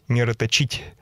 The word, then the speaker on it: мироточить: Кстати, по «Эхо Москвы» сказали, что правильно ударение ставить «мироточить».